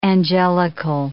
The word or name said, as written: angelical